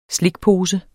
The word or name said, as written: slikpose